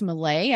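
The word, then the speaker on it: Millet: and painter Francis Davis Millay. I hope I'm saying that right. – Yes.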